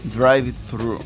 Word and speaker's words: drive-thru: Drive-thru.